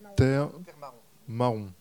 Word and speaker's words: Termaon: Son dernier roman, Termaon, c'est juste comme je prononce ? – Termaon. – Termaon.